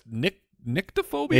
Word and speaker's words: nyctophobia: It's NYCTO. Nyctophobia. That's how you pronounce it.